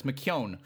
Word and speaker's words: McKeown: But it's McKeown, rhymes with McBone.